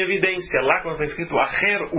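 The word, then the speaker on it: Acher (אחר): E lá a Torá fala sobre, então, deuses outros, se diz Acher. Acher. Se escreve Aleph, Het, Reish. Como se escreve Echad, um, Deus único, Aleph, Het, Dalet.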